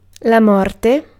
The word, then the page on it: morte: IPA: /ˈmɔrte/